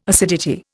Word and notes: acidity: Indian

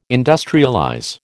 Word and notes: industrialize: American English

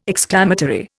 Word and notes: exclamatory: Indian

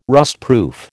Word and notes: rustproof: American English

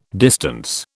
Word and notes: distance: British English

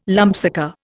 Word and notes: lumpsucker: Indian

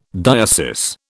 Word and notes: diocese: British English

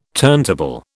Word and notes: turntable: British English

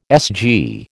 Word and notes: SG: American English

